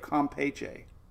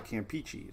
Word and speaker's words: Campeche: It's not peach, it's peach. Compache the Bay of Campeche. For years, I've been calling it Bay of Campeche.